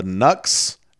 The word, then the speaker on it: Knux: and they're playing with the Nux and the Geordie Towers. – How do you spell Nux? – K-N-U-X. – It's another silent K.